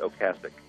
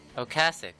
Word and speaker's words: Ocasek: We found out he likes to pronounce it Ocasek. – Ocasek. All right, well, that's what I'll say from now on. – Yeah, I used to say Ocasek all the time, too.